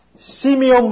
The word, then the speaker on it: Simeon: Simeon, bây giờ chúng ta đọc là Simeon.